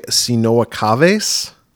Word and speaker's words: Sinoa Caves: Music by Sinoa Caves. Am I pronouncing that correctly? I think so. – I think it's Sinoa Caves because it's actually a guy named Jeremy Schmidt.